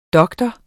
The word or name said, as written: doktor